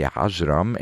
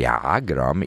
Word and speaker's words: Ajram: Her name is Nancy Ajram. In Egypt, they pronounce her name as Nancy Agram. Either way, it's spelled in English as A-J-R-A-M.